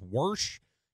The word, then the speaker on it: wash: A lot of people up here in Wisconsin, the older folk pronounce wash as worsh.